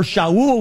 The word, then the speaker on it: Shaul: It just said he had a Hebrew name, Saul or Shaul, we would pronounce it in Hebrew, and he had a Greek name, Paul.